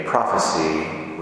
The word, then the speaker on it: prophecy: It's spelled P-R-O-P-H-E-S-Y. It is both a verb and a noun. When you speak of a prophet who is giving a prophecy, we pronounce it prophecy. In this particular case, it is actually a verb. It is pronounced prophesy, but it's spelled the same, so there's no way to know unless you know whether we're speaking about the verb or the noun.